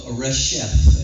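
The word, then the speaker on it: Resheph: The arrow that flies by day is a demonic devil by the name of Reshef, and his name is spelled R-E-S-H-E-P-H. R-E-S-H-E-P-H.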